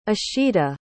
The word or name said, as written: ishita